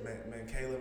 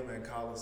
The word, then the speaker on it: Mancala: That's how you say it? – Mancala, Mancala, same way. – Mancala, got you.